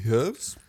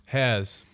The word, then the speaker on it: Herrs: Herbs? – Hairs. Like, hairs? – H-E-R-R-S. So, it's either hairs... like, that's how you would pronounce it in German.